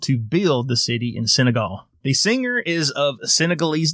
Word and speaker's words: Senegal: Yeah, Senegal.